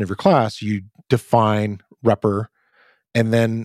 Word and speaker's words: repr: the one that is pronounced repr, but it's double underscore R-E-P-R double underscore.